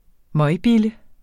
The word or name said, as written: møgbille